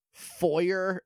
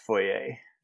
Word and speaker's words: foyer: They go into this weird, like, foyer area with, like, some pillars, and it's foggy. – I believe it's pronounced foyer. – You're right. It is foyer.